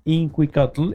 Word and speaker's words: Inquicatl: O sea, Inqui, lo voy a decir de letra, cómo se escribe Inquicatl, es Inquik de kilo, Atl.